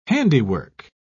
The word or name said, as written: handiwork